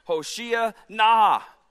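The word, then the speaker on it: Hoshea na (הושיעה נא): If you know Hebrew, you're yelling, "Hoshea na." That can sound like something else, so you have to pronounce it carefully. Hoshea na.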